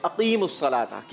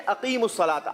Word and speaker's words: अकीमुस्सलाता: आपने कहा अकीमुस्सलाता, बिल्कुल गलत है, क्योंकि सही जवाब है अकीमुस्सलाता। क्या है? अकीमुस्सलाता। जवाब वैसे हम आपका दुरुस्त मान लेते हैं। 30 पॉइंट्स फैजान एकेडमी के लिए। – सुभान अल्लाह। – जी सही प्रोनाउंसिएशन है अकीमुस्सलाता, अकीमुस्सलाता नहीं है। ठीक है बेटा,